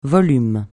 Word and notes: volume: francais

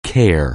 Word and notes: CARE: anglais

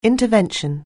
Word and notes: intervention: anglais

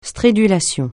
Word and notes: stridulation: francais

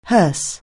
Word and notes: hearse: anglais

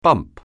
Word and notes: bump: anglais

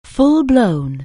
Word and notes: full-blown: anglais

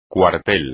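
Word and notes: cuartel: español

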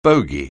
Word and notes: bogey: anglais

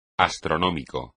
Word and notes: astronómico: espagnol

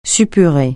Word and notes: suppurer: French

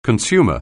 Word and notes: consumer: anglais

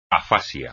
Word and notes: afasia: espagnol

